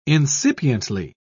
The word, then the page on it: incipiently: British English